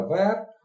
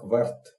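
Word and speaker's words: vert: Зеленый – вер, вернее, по-французски это вер, а по-английски его произносят как верт.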